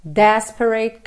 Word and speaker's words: desperate: Desperate caress. Atenção aqui às sílabas tônicas. Desperate é a primeira, caress é a segunda, ok?